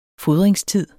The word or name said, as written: fodringstid